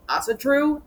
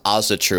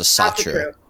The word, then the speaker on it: Asatru: And apparently that's how you pronounce Asatru. I always thought it was a Satru. – Yeah. Asatru. The Asa are okay. The gods. So it's you're true to the Asa.